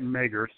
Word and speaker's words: Mager: How do you pronounce that last name? – Dr. Robert Mager.